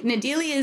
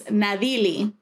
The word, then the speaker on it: Nadyli: And what I mean by that is Nadyli is not my name, right? Nadyli is the Americanized pronunciation of it. My name is Nadyli. That's what it's supposed to be like. It's like a light D.